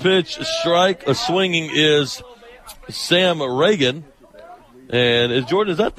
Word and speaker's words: Ragan: I think it's Sam Reagan. That's how I've got it written down, R-A-G-A-N. – Okay. – So that's how I would pronounce it.